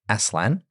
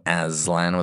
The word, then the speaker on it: Aslan: Aslan is Turkish for lion, and Jack prefers it prefers it to be pronounced as Aslan uh, rather than Aslan with a hard S or Z.